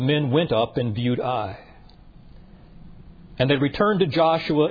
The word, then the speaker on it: Ai: Just notice, by the way, it is spelled Ai, but pronounced Ai.